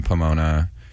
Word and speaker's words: Pomona: So he came out to our show in Pomona.